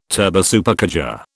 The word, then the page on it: turbosupercharger: British English